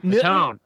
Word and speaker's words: Newtown: It's not Newton. – Worcester. – Newton. – Quincy. – Newton. – Newtown.